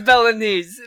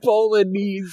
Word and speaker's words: Bolognese: a seven-course Bolognese. Oh. – At one point he says he makes a Bolognese feast. Fuck you, Flash. – I thought he like pronounced the G and everything too.